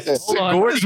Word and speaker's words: Sigourney: Sigourney.